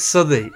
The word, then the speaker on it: Southey: No, it's Southey. It's really important. – That's an in joke, just for the listeners. – From the offset, that it's spelt Southey, said Southey.